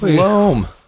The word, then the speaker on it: loam: Oh, I said loom. – Loom. – It's not loom. – People call it loom. – I know, everyone calls it loom, but it's pronounced loam.